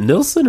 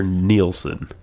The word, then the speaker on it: Nilsson: Is it Nilsson or Nielsen? – I think it's... Well, it's spelled like Nilsson. – Like no son. – Nilsson. – I think it's Nilsson.